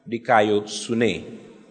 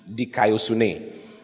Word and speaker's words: dikaiosune: Dikayosune. D-I-K-A-I-O-S-U-N-E. Dikayosune.